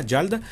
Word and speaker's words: Yalda: Hakim, o sea, Yalda se escribe con Y, como suena, Yalda, y Hakim o Akim se escribe con H, H-A-K-I-M.